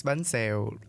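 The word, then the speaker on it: bánh xèo: I can spell it out to you. It's B-A-N-H and then X-E-O. – Oh, it's banseo.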